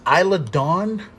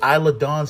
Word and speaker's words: Isla Dawn: Uh, the first matchup is going to consist of Alba Fire to take on think her name is Isla Dawn.